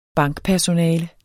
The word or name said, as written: bankpersonale